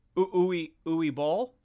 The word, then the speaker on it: Uwe Boll: Yeah, is that how you pronounce that? – Uwe Boll? – Yeah. – Yes, I believe so.